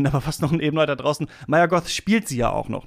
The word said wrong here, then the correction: Mia Goth: ja tatsächlich auch Mia Goth, ja, äh, spricht man sie so aus, ja.